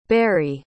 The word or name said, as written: bary